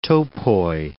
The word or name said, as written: topoi